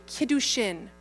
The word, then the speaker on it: Kiddushin: That means in the marriage process, they were about to enter a period known as the Kiddushin. K-I-D-D-U-S-H-I-N, the Kiddushin.